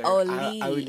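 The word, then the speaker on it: Auli'i: However you say Oli. It's like Cravalho. A-U-L-I apostrophe I.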